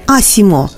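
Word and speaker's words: ASIMO: В японском языке имя робота произносится как «Асимо», созвучно со словосочетанием «а также ноги».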